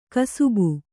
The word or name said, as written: ಕುಸುಬು